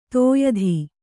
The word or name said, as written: ತೋಯದ